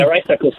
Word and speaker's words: Rice-Eccles: Yeah, Rice-Eccles Stadium.